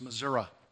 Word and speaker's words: Missouri: Although if you're from my part of Arkansas, it's Missouri, which is probably not the right pronunciation, but that's the way we say it.